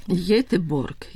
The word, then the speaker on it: Göteborg: Göteborg. – Göteborg, ja, ne z G-jem.